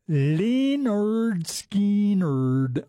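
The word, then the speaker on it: Lynyrd Skynyrd: It is 10:40 and 20 seconds, and we just heard from Leonard Skinner, pronounced Leonard Skinner.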